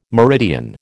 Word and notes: meridian: American English

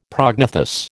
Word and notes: prognathous: American English

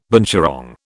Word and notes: binturong: British English

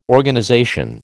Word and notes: Organization: American English

